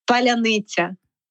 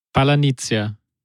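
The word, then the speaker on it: паляниця: It's called palyanytsya. – Palanitsia. – Oh, you see, no, you sound definitely very German to me.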